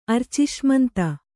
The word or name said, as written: ಅರ್ಚಿಷ್ಮಂತ